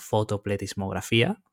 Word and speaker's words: fotopletismografía: Esa tecnología que Pablo describió, que tiene una palabreja muy graciosa que se llama fotopletismografía.